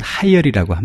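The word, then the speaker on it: 하이얼이라고 (海尔): 예, 오늘은 중국의 대표적인 기업 가운데 하나인 헤이, 중국어로 발음하면 하이얼이라고 합니다.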